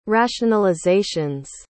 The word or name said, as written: rationalizations